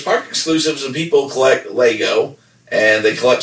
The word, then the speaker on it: Lego: Probably a lot of people collect Lego, or I always say Legos, but like Nevada, it's pronounced Lego.